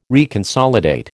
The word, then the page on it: reconsolidate: American English